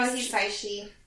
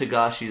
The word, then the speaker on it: Hisaishi: Joe Hisaishi. – I always thought it was Higashi as well, but no.